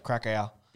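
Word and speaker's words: Kraków: Krak. It's pronounced Krakow.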